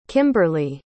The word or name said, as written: kimberlie